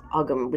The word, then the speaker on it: Ogham: Ogham, spelled O-G-A-M and pronounced Ogham, which is a more modern way of pronouncing it. Ogham is the ancient Irish way of pronouncing it, where it's O-G-H-A-M and the H makes the G silent. But there's a terrific book by Aaron Rowan Laurie called Ogham, O-G-A-M, Weaving Word Wisdom.